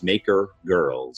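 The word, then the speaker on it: MakerGirl: So we talk about that big point out there, Julia, which is really as a founder of this, it's called Maker Girl. You got to pronounce the R, right? So, I say that because of accents. People say Maker. No, it's Maker Girl.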